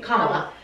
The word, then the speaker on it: Kamala: Kamala Harris. How do you say her name? – Kamala.